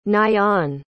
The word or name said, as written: nayan